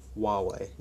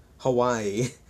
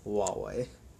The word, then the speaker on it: Huawei: So we have, moving away from Computex, there's all this new stuff about Huawei, and yes, that is actually their pronunciation, Huawei, not Hawaii, Huawei.